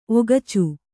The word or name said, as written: ಒಗಚು